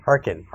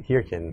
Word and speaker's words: Hearken: Harkin. Harkin. Not Hirkin. Not Hirkin. It's spelled Harkin. Harkin.